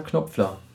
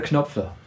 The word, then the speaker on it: Knopfler: So, und er sagt, dass man eigentlich, ja, wenn es deutsch ist, ist es ja Knopfler. Und das kommt nur durch die englische Aussprache, da die KN Knopfler sprechen. – Ja. – Und seitdem wir das wissen, sagen wir alle Knopfler.